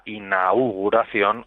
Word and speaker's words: inauguración: Aquí eh, lo, adecuado es mm, mantener el diptongo, entonces hay que decir eh, sesión inaugural o la inauguración o inaugurar, siempre con la U, no tan marcado como lo acabo de hacer yo, ¿no? Eh, una pronunciación relajada, pero que, que se mantenga tanto la A como la, como la U.